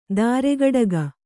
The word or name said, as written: ತ್ರಿಕಟುಕ